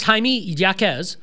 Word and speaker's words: Jaime Jaquez: It's pronounced Jaime Jaquez.